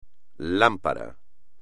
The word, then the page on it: lámpara: Spanish